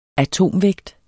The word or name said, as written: atomvægt